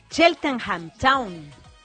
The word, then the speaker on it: Cheltenham Town: Cheltenham Town.